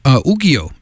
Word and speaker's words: Ukiyo: That is new music from Ukiyo, as it's pronounced, U-K-I-Y-O.